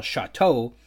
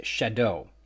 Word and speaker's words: Chateau: Finally, thanks to my awesome guest Douglas Henderson in episode 27, The Tour, I can say that I've been incorrectly pronouncing the Montana locality and chapter title Chateau like some sort of francophony and stinking Canadian might. Uh, when it's actually pronounced Shadow.